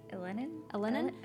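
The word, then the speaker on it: Elenin: And that's actually in 2011 when the comet Ilinen, is that how you say it? – Ilinen. – It appeared. Ilinen.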